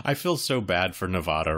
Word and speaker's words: Nevada: Apparently, it's Nevada. That's the preferred pronunciation of that state.